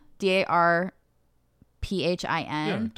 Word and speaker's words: Darphin: But the thing that seemed really cool to me is the, I don't know how to say it, Darphin, Darphin, D A R P H I N.